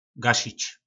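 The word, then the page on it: Gašič: nářečí Po našimu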